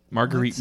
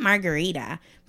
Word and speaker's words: Marguerite: Marguerite Monday? – Marguerite. Not Margarita. Marguerite. And she always makes sure you say Marguerite. Like you really have to pronounce the U.